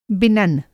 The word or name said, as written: बिनन